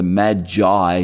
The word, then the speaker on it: Magi: They hear the nativity scene, they hear about the shepherds, they hear about the wise men, also known as the Magi, or pronounced sometimes the Magi. If you're in America, they tend to emphasize those vowels.